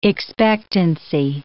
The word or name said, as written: expectancy